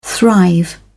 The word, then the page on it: thrive: British English